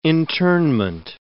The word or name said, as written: internment